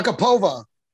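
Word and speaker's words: Agapova: how does it Akababa or Agababa? I'm butchering the name, but she, the girl out Agababa outlanded. – Oh, Akapova.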